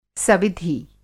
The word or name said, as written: सविधि